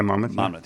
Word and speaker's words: Monmouth: How do you say that? Monmouth? – Yeah, Monmouth.